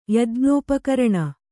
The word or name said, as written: ಯಜ್ಞೋಪಕರಣ